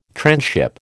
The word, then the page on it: transship: American English